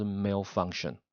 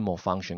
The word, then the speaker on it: malfunction: 改成好，因为 mal 是坏嘛，所以像有时候我们说故障是 malfunction，或者是 malfunction，看你怎么念，malfunction、 malfunction 都有人念，就是故障，因为你在 function 功能前面加 mal，就是坏坏掉的功能，就是故障。